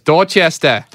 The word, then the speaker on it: Dorchester: They say he was raised in Dorchester neighborhood of Boston, Massachusetts. – You have to say it like this, Dorchester.